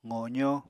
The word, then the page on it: qozó: apatani